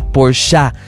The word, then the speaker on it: Porsche: Y él dice, no es Porsche, es Porsche. – Es Porsche. – Porsche. Sí, es correcto. Se pronuncia Porsche. Son dos sílabas.